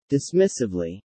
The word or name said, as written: dismissively